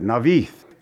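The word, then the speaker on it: Naioth: Now, it doesn't look like that, but that's how you pronounce that, Navith.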